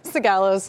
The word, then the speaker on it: Sigalos: Sigalos.